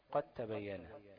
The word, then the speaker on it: قد تبين: مثال قد تبين، ديت مش هتنطق الدال، تنطق ايه؟ تاء واحدة. قد تبين، ماشي، مفيش دال بقى، مش قد تبين، لا قد تبين، ماشي.